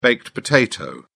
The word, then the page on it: baked potato: British English